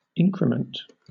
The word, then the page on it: increment: British English